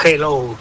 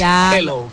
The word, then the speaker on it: hello: Y la segunda, dile a Elena que su dicción en castellano es la más perfecta que conozco, porque cuando diga hello, que no pronuncie la J, que no es hello, que es hello, que la aspire la J y no pronuncie la J potente castellana.